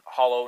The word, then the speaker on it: Holo: H-O-L-O chain. – Okay. – Some people pronounce it hollow and some people pronounce it halo. But I think the word originally came from the idea of holographic, so holographic uh-huh.